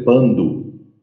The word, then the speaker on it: Pandu: Então a pronúncia é Pando.